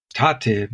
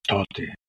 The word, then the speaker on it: Tate: And Pinya Ber spoke a subdialect of that from that Khersonia Gubernia Bessarabia, people call it collo- colloquially Totemomolution, because Tate, meaning father, in that area is pronounced Tote.